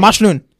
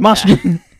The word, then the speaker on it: Mashnoon: So now when it's people so mispronounce my name, like, it's Mashnoon. My name is Mashnoon.